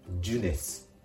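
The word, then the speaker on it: jeunesse: この単語はほぼカタカナ発音で大丈夫です。ジュネス、ジュネス。あえてで言うといえば、 5。末の S の発音。これをしっかり芯のス、スとして発音してあげるところがポイントです。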